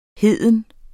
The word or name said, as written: heden